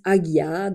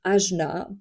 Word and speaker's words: Ajna: Il y en a qui disent Agya, d'autres Ajna. Prononcez-le comme vous voulez.